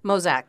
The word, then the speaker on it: Mauzac: And you're saying that if this one, it means it's the Mauzac wine? Mauzac? Yeah. Mozac. That's, Mozac. That's how they were saying it there. I it, know it's spelled, um, how's it spelled? M A U Z A See?